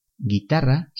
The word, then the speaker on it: guitarra: Guitarra se deletrea G U I T A R R A. La letra U no se pronuncia, recuerda.